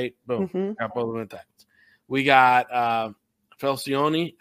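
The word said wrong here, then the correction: Feliciano: at the center, right? – Feliciani, yeah. – Feliciani at center. So we got three spots.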